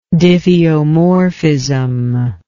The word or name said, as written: diffeomorphism